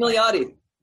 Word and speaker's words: Milioti: Kristen Milioti. – Milioti.